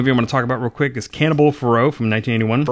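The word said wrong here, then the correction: Ferox: Farrox. It's actually pronounced.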